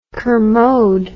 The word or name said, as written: kermode